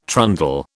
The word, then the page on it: trundle: British English